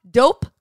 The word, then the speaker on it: Doughp: But Dope Cookie Dough is changing everything, and that is spelled D-O-U-G-H-P.